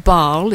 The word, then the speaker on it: Bâle: Well, Strasbourg first, changed the train, and then through to, I think it's pronounced Bâle. It's spelled B-A-S-E-L in Switzerland.